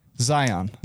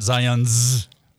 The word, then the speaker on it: Zion: Singular. – Zion. – Not Zion's. – Okay. – Zion. – Thank you. – You said Zion.